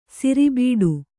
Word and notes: ಸೆರೆಬಿಡು: sere biḍu

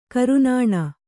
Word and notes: ಕೋರಾನ್ನ: kōrānna

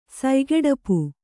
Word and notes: ಸಯ್ಗೆಡಪು: Kannada

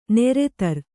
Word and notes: ನೆರೆತರ್: neretar

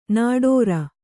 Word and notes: ನಿಟ್ಟೂರಿ: niṭṭūri

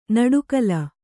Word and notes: ನುಡಿಗಲ್: nuḍigal

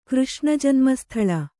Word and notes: ಕೃಷ್ಣಜನ್ಮಸ್ಥಳ: kannada

